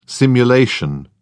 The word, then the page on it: simulation: British English